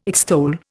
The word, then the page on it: extol: Indian